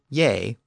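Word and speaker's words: ge: It was actually spelled G-E in Old English, but we know that that G was often pronounced with a Y sound. And that form, ye, was the subject form.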